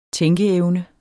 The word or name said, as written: tænkeevne